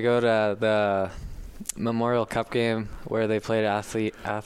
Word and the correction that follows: Acadie: Bathurst. Is that how you say it? – Acadie Bathurst.